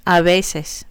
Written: a veces

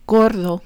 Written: gordo

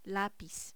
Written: lapiz

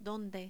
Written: donde

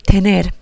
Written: tener

